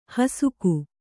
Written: ಹೊಸಕು

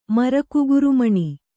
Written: ಮರಕುಗುರುಮಣಿ

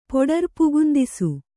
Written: ಪೊಡರ್ಪುಗುಂದಿಸು

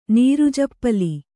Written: ನೀರುಜಪ್ಪಲಿ

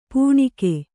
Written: ಪೆಣಕೆ

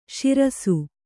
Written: ಶಿರಿಸ್ಸ

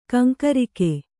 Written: ಕೆಂಕರಿಕೆ

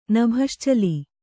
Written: ನಭಶ್ಚಲಿ

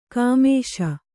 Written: ಕುಮ್ಮಿಸು

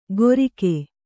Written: ಗೊರಿಕೆ

